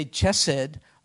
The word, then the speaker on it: chesed: I told you it's the Hebrew word chesed, chesed. We can spell it C-H-E-S-E-D, but you don't pronounce it chesed. If you say chesed around the Jewish person, that Jewish person will be amused. It's chesed. An H sound in Hebrew is ch, like it's like clearing your throat.